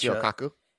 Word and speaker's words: Michio Kaku: The Asian guy, Mikio. I watch Michio Kaku.